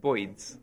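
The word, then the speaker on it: Boids: And this this man who had invented a program called Boyds, which is like bird spelt with an O in it, Boyds, as in a boid, a birdoid.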